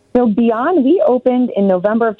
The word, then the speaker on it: Bian: It's spelled B-I-A-N. Let's pronounce it Beyond.